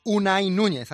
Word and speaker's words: Unai Núñez: para hablar de Unai Núñez, ahora lo he dicho bien.